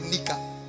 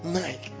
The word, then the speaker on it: Nike: And the guy was asked to pronounce Nike. He said Nika. Nike. This is Adidas.